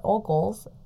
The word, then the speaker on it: Ogles: And, you know, I had a hard time saying his name, but again, that was Mr. Ogley, or not Ogley. – Ogley Bogley. – Where did I put it? – Heather found that it is pronounced Mr. Ogles, O-G-L-E-S.